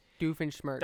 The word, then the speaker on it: Doofenshmirtz: Doofenshmirtz.